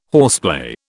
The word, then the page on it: horseplay: British English